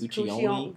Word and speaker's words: Guccione: Guccione. – Guccione.